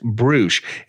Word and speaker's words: Brüush: It's Brüush, spelled B R U U S H, pronounced Bruce.